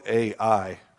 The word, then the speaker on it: Ai: And then they come to the next city, which is called Ai, or I, however you want to pronounce it. It's spelled A-I, so I call it Ai.